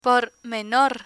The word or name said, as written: por menor